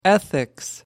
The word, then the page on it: ethics: British English